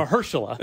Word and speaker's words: Mahershala: Season Mahershala. – 2 never happened. – Mahershala.